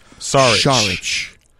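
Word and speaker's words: Šarić: So he's pronounced like a S-H. – Saric.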